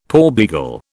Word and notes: porbeagle: British English

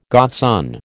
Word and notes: godson: American English

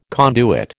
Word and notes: conduit: American English

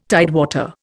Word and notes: tidewater: Indian

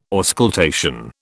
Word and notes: auscultation: British English

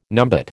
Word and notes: numbat: American English

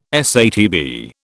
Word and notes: SATB: British English

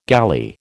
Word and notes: galley: American English

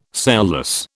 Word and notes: sailless: British English

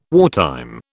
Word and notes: wartime: British English

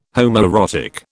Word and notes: homoerotic: British English

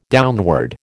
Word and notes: downward: American English

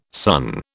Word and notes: sunn: British English

